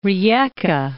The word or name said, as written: Rieka